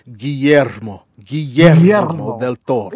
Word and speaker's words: Guillermo del Toro: This is the new movie by Mexican director Guillermo del Toro. – You have to say it like this. Guillermo del Toro. – Oh, there. – Guillermo del Toro.